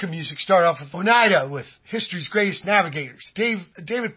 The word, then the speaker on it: Oneida: I've already forgot how to pronounce it, Brother Matt. Oneida? Oneida? – Yeah. – It is Oneida. Okay. Oneida.